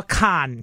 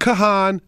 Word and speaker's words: Kahan: So even though his his name looks like it's two syllables, it's one. – Is there confusion with his name? – The internet has a lot of TikToks saying this is how to say his name, but you have to go to his personal TikTok where he says, no, no, my name is Noah Khan. – Not Kahan, not Kahan.